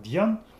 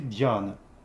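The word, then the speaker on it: дхьяна: На хинди произносится как дьян, конечный атом обрубается, а на санскрите дьяна.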